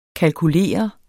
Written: kalkulere

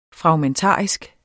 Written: fragmentarisk

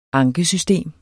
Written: ankesystem

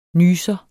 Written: nyser

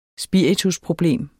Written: spiritusproblem

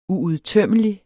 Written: uudtømmelig

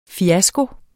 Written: FIASKOERNES

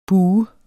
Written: bue

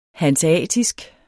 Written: hanseatisk

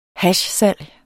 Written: hashsalg

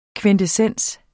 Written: kvintessens

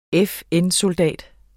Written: FN-soldat

